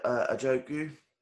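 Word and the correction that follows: Ogunjobi: Larry Ogunchobi.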